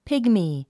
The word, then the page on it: pygmy: pígmi; ピグミー